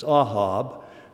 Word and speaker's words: Ahab: King Ahab. But I got to thinking, it's interesting that he chose that name for that king, because Ahab in Hebrew, it's Ahab, there's no long A, means love.